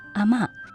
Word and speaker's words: 阿嬷: 近期电影《给阿嬷的情书》在新加坡、马来西亚、文莱等多个国家与地区上映，片名当中的阿嬷在方言当中读作阿嬷。